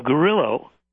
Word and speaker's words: Grillo: A man by the name of Grillo, rhymes with Brillo, surprised things.